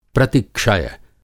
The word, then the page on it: प्रतिक्षय: Hindi